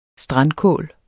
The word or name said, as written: strandkål